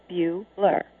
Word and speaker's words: Bueller: It is pronounced view, like beautiful, Bueller.